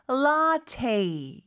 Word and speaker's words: latte: 자, 일단 latte는 라떼이겠네요. 그렇죠? 발음이 조금 다르네요. 그렇죠? 영어로는 latte 이렇게 발음을 합니다.